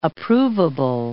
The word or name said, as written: approvable